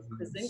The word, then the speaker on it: Krasinski: Krasinski.